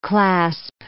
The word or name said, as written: clasp